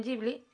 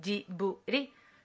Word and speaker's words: Ghibli: internacionalmente lo pronuncian Ghibli y en japonés lo pronunciarían como Jiburi, que si lo dice rápido es un Jiburi. Suena parecido a Ghibli, pero como no tienen el sonido BL, pues hacen Jiburi.